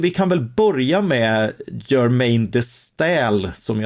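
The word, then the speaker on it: Germaine de Staël: Ja, alltså Germaine de Stal, det är inte lätt att uttala, men så, så ska det uttalas.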